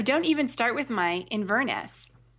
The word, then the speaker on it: Inverness: Inverness. – Inverness. So the emphasis is on the in.